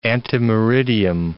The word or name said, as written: ante meridiem